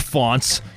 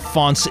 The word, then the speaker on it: Faunce: And you know, if you want to learn more about Hank Fonts, just type his name. His last name is spelled F-A-U-N-C-E. So type his name, Hank Fonts, into Google.